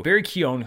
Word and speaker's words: Keoghan: Barry Keoghan. And I've never known how to say his name. I had to look up. I finally found a radio interview. Barry Keoghan, who is apparently our next Joker, which I'm glad that they were able to keep that under wraps.